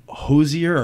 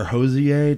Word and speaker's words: Hozier: This next song, it's called Jackie and Wilson by Hozier or Hozier, depending on if you... like the French pronunciation or not.